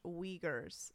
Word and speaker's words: Uyghurs: Mike P. says, pronounced Uyghurs. Still slightly mispronounced in my Midwest accent.